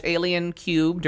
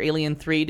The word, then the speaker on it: Alien 3: So I have been meaning to, for years, go back and watch Alien Cubed or Alien 3, depending on how you want to pronounce it.